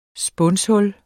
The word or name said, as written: spunshul